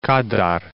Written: Kadar

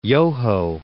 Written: Yoho National Park

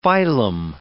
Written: phylum

